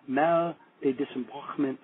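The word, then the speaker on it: Mal de Débarquement: And the proper pronunciation is Mal de Disembarquement Syndrome.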